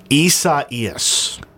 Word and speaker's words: Isaias: I saw a phonetic pronunciation this morning. Isaias.